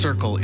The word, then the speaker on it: CRCL: CRCL, pronounced Circle, is a mobile, community-led crisis response service in the Comox Valley.